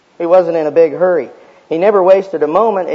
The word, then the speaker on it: Hari: The Hindus worship a god, and it's spelled H-A-R-I. It's pronounced Hurry, the god of hurry.